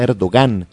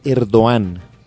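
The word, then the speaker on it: Erdoğan: Es que sabe que estoy hablando con unos amigos de la comunidad turca y me corrigieron la pronunciación porque yo decía Erdogan. – ¿Todos? – No. Se dice Erdogan.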